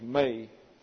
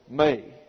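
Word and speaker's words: me: That's M-E pronounced. This is your eta, so it gets an A sound in the English, may, phobeo, may.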